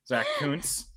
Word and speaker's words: Zach Kuntz: Yeah, don't talk about Zach Kuntz. That's how you pronounce that name, Jamie. Zach Kuntz.